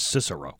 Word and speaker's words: Cicero: I was like, how would you not know that that's pronounced Cicero? – Right.